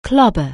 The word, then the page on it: clobber: anglais